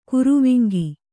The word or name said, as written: ಗೊರವಂಕ